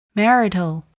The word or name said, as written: marital